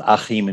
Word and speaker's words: Achim: My first name is pronounced Achim in Germany, but I grew up all over the world.